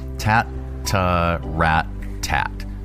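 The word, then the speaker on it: tattarrattat: The longest palindrome in the English dictionary is, how do you pronounce that? – Tatarata. – Tatarata. Is that really how you pronounce it? Or is that just that French guy's pronunciation? Here. Tat-ta-rat-tat.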